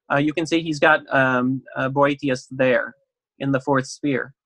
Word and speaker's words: Boethius: Boethius, or some people pronounce him Boethius, was the first Christian scholastic.